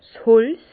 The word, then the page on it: 's Holz: Bas Rhin; Herrlisheim